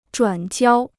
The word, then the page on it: 转交: zhuǎn jiāo